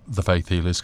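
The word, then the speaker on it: Th' Faith Healers: That is Faith Healers, and it's pronounced the Faith Healers because they've dropped the E of the.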